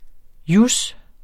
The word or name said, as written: jus